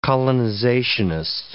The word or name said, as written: colonizationist